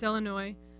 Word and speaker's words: Illinois: You said Illinois, and the first one is there's a lot more French words, and we do tend to mostly pronounce them correctly. It's Illinois.